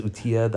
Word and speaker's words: Utila: on Utila, Utila, I think it's Utila, the island. That's how you pronounce it. U-T-I-L-A.